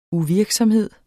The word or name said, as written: uvirksomhed